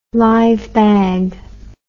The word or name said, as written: live bag